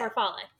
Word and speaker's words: farfalle: I feel like need to know how it's pronounced. Farfalle? Oh, far farfalle. – That's it. Farfalle.